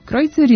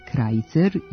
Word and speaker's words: Krojcer: Prema današnjim pravilima prenošenja s nemačkog, sonata je Krojcerova. Međutim, mnogo ranije, na ovim prostorima ustalio se pogrešan izgovor Krajcer